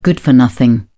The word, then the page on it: good for nothing: British English